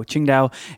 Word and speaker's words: Tsingtao: It's spelled T-S-I-N-G-T-A-O, Qingdao, and a spicy shrimp dish.